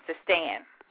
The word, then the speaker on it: Seistan: They came to be known as Sakae, and that's spelled S-A-C-A-E, and their chief city was Sekistan, now Sistian, or Sistian, it's spelled S-E-I-S-T-A-N, Sistan.